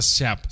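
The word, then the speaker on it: Giap: se, creo que se pronuncia Siap. Se escribe Hiap.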